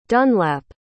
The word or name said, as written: dunlap